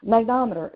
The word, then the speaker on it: magnetometer: Magnetometer, am I saying that right? Magnometer? – I don't know. – Magnometer. Yes, magnetometer.